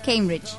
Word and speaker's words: Cambridge: A ver, pronuncia otra vez Cambridge. – Cambridge. – Ahí está.